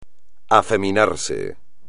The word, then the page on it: afeminarse: espagnol